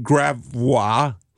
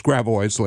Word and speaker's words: Gravois: For example, we have Gravois. No, it's Gravois, ladies and gentlemen, of course.